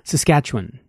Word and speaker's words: Saskatchewan: Saskatchewan.